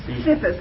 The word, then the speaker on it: Cephas: And James, I can't pronounce that word. – Cephas, I'm sorry.